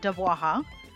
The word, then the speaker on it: DABWAHA: And now, without any further ado, and yes, it's pronounced DABWAHA, on with the podcast.